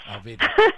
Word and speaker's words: Alveda: She's got a story called Glimmer that has, I'm going to mess up her name, Alveda King. Did I say it right? – Yeah, Dr. Alveda King, yes. – Alveda.